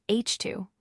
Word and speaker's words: HII: When atomic hydrogen is ionized, it forms what are known as HII, pronounced H2, regions, which are visible in this image as concentrations of bright pink light.